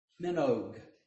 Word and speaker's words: Minogue: Kylie Minogue.